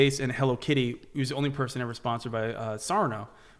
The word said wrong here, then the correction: Sanrio: which is, I think I'm saying it right, Sanrio? – Sanrio. Yep.